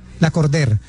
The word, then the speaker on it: Lacordaire: Lacordaire. – Pero como en francés, como Lacordaire. Sí. Una cosa así. Sí. – Es que es un nombre francés y se escribe Lacordaire, pero se pronuncia Lacordaire.